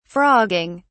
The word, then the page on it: frogging: British English